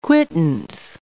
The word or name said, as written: quittance